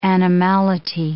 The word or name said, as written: animality